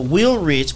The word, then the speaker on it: Willrich: So my name is pronounced Tony Will Be Rich. – Right. – So Willrich, but you just gotta add the B in there.